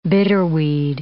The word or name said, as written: bitterweed